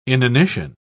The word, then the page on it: inanition: British English